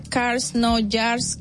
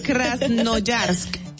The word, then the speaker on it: Krasnoyarsk: Krasnoyarsk de Rusia, señor. Pero sí lo dijiste este bien, Krasnoyarsk.